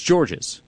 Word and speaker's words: George: and he confirmed the S is silent, said it's George. Some people call me George's, which I don't mind, but if my mom hears it, she might get mad a little bit.